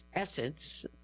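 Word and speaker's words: SSENSE: Quote, "It's a game changer," Rami Atala, the chief executive of Sense, pronounced Essence, said of the planned move in an interview.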